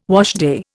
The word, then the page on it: washday: Indian